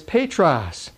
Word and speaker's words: Petros: is Petros, which is spelled P-E-T-R-O-S, Petros, and it means a small stone.